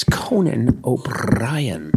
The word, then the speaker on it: O'Brien: It's Conan O'Brien. Roll that R.